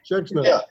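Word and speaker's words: Schexnayder: But it says the program will feature remarks by Representative Clay, how do you say his last name? Schechtsnyder? – Schechtsnyder. – Is that how you say it? – Yeah.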